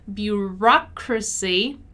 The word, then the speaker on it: bureaucracy: While in bureaucracy, my stressed syllable is the ra, bureaucracy, bureaucracy, bureaucracy.